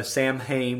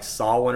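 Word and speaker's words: Samhain: I think it's actually pronounced like Solwyn or something like that. But we're Americans. We pronounce it how it's spelled out. So for years, everybody called it Sam Hain, and then the yeah. Irish were like, "No, it's Solwyn."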